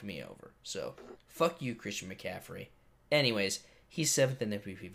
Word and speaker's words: Christian McCaffrey: You've got Christian McCaffrey. butchered that. Christian McCaffrey.